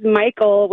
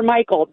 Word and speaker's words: Michel: My married name is Michael, which sounds fine, but it's spelled M-I-C-H-E-L. So I get Mitchell, Michelle. Like, it's never Michael, never.